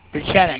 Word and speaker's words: Pieczenik: His name is Steve R., I'm going to spell it rather than pronounce it, P-I-E-C-Z-E-N-I-K. – Pichenik.